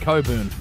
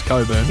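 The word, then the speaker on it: Cockburn: I don't care how you spell it. It's pronounced Coburn. – How do you spell it? That's a question. – No. – C-O-C-K.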